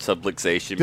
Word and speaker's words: subluxation: So I love that word, subluxation. – Did I say it right? – You said it perfectly, but it's funny because it's so, you know, it's just this just proper term.